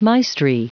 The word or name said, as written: maestri